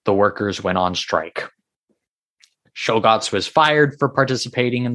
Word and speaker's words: Czolgosz: I don't know how to pronounce his name now all of a sudden. Czolgosz. It's Czolgosz. Leon Czolgosz. He was born in Michigan, 1873.